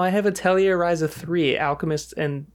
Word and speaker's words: Atelier: Isn't it like a French pronunciation? – Atelier. – Atelier.